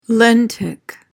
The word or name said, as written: lentic